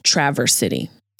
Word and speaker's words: Traverse: Traverse City, I know, but the word, the actual word is traverse, to traverse a city. But ironically, maybe not so ironically, this city is pronounced Traverse City.